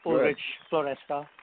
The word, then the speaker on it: Ulrich Floresca: It's Ulrich Floresca.